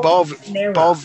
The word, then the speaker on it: Balvanera: I like your pronunciation. What balls in my Venera? – Balvanera. – Balvanera. Yeah, I believe it's Balvanera.